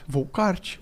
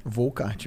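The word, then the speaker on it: Wolkart: Volcart. – Como, ah, é, que, como é que pronuncia essa porra? Volcart mesmo. É Volcart é. mesmo. Tá, mas ó, é W-O-L-K-A-R-T.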